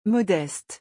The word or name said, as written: modeste